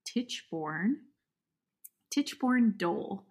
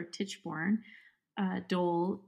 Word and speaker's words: Tichborne Dole: So Tichborne, new word, dole, like D-O-L-E, like pineapple.